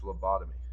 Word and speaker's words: lobotomy: Is it lobotomy or lobotomy? – It's lobotomy.